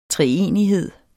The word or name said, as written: treenighed